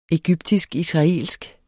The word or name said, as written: egyptisk-israelsk